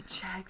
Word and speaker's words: Czech: The Czech, that's how you say it? – That's how you say that.